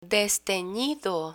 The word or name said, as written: destenido